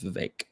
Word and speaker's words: Vivek: Yeah, I'm pretty sure it's Vivek. It rhymes with cake. He said that. – The E makes an A.